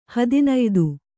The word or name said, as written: ಹದಿನಯ್ದು